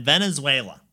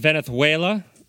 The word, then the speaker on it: Venezuela: When these guys were touring in Venezuela, they came across a flamenco dancer. – I think you mean Venezuela and flamenco.